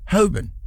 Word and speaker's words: Holborn: That we pronounce the L, but the L actually isn't pronounced Hoban.